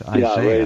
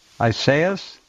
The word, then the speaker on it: Isaias: It's Hurricane Isaiah, but it's got an S on the end of it. It's not just Isaiah.